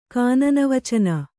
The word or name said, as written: ಕಾನನವಚನ